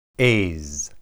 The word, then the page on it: ez: hebrew